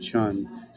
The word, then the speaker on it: Chen: Bretton Woods Agreement and the institutions it created explained by James Chen, or actually it would be pronounced Chun, C-H-E-N, if we're looking at the real Chinese pronunciation of it.